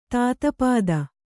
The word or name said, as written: ತಾತಪಾದ